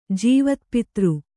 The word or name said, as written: ಜೀವತ್ಪಿತೃ